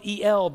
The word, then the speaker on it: El: It's spelled E-L, but it's the word El.